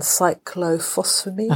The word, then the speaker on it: cyclophosphamide: I had 4 sessions, and I had doxotaxel and cyclophosphamide.